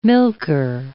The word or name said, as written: milker